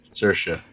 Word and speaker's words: Saoirse: I'm going to butcher this name, and I hear it all the time, but Saoirse Ronan? – Saoirse.